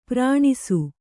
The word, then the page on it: ಪ್ರಾಣಿಸು: kannada